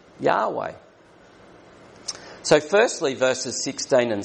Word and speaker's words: Yahweh: And most people who study Hebrew say this probably should be pronounced Yahweh rather than the older Jehovah.